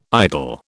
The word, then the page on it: idle: British English